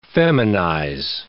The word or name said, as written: feminize